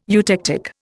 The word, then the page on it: eutectic: Indian